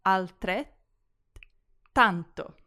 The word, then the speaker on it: altrettanto: Next, the double T's in the middle of the word altrettanto. With the double letters in Italian, you almost need to pause between them. So, altrettanto.